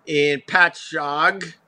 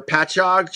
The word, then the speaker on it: Patchogue: First time my wife saw the exit sign for Patchogue, she pronounced it Patchogue because we have some very, very strange Indian names out there on Long Island. And she's looking at me like, it's pronounced Patchogue, not Patchogue.